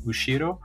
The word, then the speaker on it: Ushiro: time but uh, yeah and then another one that I, just comes to mind is hawaiian artist called edwin ushiro uh, so it's uh, Ushiro.